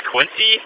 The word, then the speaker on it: Quince: I think it's pronounced Quincy, Q-U-I-N-C-E.